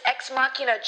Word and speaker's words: Ex Machina: Yes, it's ex machina, Joe. Even Demo got it right.